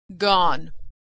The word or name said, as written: gone